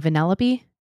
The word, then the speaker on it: Vanellope: Vanellope.